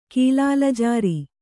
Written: ಕೀಲಾಲಜಾರಿ

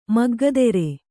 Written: ಮಗ್ಗದೆರೆ

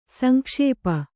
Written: ಸಂಕ್ಷೇಪ

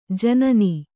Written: ಜನಾನಾ

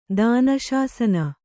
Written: ದಾನಶಾಸನ

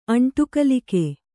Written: ಅಂಟುಕಲಿಕೆ

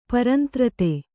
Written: ಪರಂತ್ರತೆ

